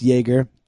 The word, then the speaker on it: Jaeger: Which his name is Elliot Jaeger, which is spelled Jagger, but it's Jaeger, like Jaegermeister.